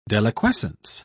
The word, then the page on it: deliquescence: British English